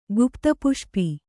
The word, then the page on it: ಗುಪ್ತಪುಷ್ಪಿ: Kannada